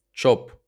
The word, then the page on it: Чоп: [tʃɔp]; Ukrainian